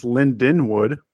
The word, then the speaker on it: Lindenwood: What do you know about Linwood? – I know that they're pronounced Lindenwood. – Oh, yeah, sorry. My bad.